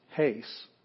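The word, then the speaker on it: hace (εἷς): Here's a rough breathing mark over epsilon iota, sigma. They look almost exactly the same, except there's a rough breathing mark. This one's hace.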